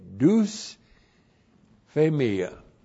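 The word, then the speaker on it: dusphemia: D-U-S-P-H, long E-M-I-A, deus phemia.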